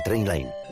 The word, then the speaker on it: Trainline: Se pronuncia Trainline.